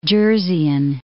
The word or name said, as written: Jerseyan